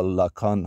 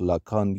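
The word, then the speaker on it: Lacan: لکان حالا ژاک لکان یا لکان بسته به اینکه فکر میکنم فرانسوی یا انگلیسی تلفظ بکنیم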